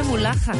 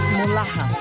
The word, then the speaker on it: Mullaha: ¿Cómo se escribe mulaja? Dinos por ahí. Oigan, M-U-L-L-A-H-A. Mulaja.